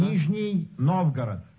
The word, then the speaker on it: Nizhny Novgorod: Nizhny Novgorod.